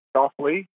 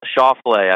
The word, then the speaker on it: Schauffele: Schauffele? Is that right? – Schauffele, I think.